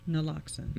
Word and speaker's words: Naloxone: Naloxone.